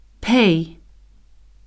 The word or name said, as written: pay